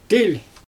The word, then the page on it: tilʲ: Xyzyl